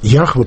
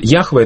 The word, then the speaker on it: Яхве: То есть Яхве это считается научным больше произношением, а Иегова бытовым, которое вошло со времен Макария.